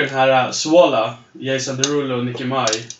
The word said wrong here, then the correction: Minaj: Minaj. Minaj. Minaj.